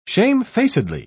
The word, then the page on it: shamefacedly: British English